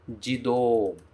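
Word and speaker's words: Jido (自動): E o kanji do, que é mover, eu formo esta palavra que se pronuncia. Como será que pronuncia? Jido. Jido.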